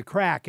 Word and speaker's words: craic: And maybe you should mention first what the title means, The Crack. And it's spelled C-R-A-I-C, which I think is Gaelic.